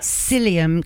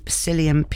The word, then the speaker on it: psyllium: Psyllium. Gosh, I need to spell it. So it's S-E. – Psyllium.